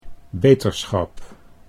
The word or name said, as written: beterschap